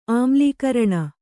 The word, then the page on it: ಆಮ್ಲೀಕರಣ: Kannada